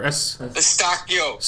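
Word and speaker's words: Eustáquio: I can never pronounce the guy's name. It's like Escudeo, some fucking the midfielder. – Eustachio.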